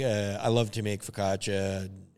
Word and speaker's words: focaccia: Also, I believe it's pronounced focaccia. – Fuck a chia, fuck a chia.